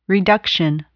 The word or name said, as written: reduction